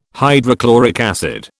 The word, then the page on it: hydrochloric acid: British English